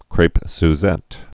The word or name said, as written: crepes suzette's